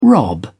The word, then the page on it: rob: British English